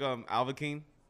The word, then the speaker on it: Alvakine: First of all, I thought it was, like, Alvakine, like, Anakin Skywalker type thing. – Yeah, yeah, you said, like, Alvakine, and, like, no, people normally say, like, there's another artist. His name is Alkaline.